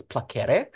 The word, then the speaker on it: placere: But placebo comes from placere, or if you want to be technically correct, because in Latin it's all hard C, so it's placere.